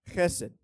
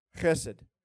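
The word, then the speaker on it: Chesed (חסד): The first word is chesed. Chesed.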